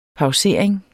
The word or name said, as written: pausering